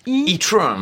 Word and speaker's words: e-tron: Alors évidemment, on est censé le prononcer à l'anglaise, e-tron, mais ça reste un bon nom de merde quand même, on va pas se mentir.